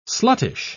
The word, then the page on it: sluttish: British English